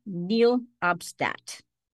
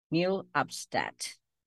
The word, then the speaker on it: nihil obstat: Fernandez advised that a declaration of, this is a Latin word that's pronounced nil obstat. Nil obstat.